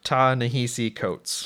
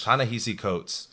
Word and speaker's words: Ta-Nehisi Coates: I said Ta-Nehisi Coates. – Ta-Nehisi Coates,